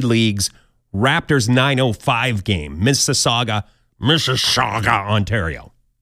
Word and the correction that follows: Mississauga: Sorry, Mississauga. I'm trying not to do any edits, so I'm going to leave the first pronunciation in where I sound a little bit like a drunken Tom Brokaw.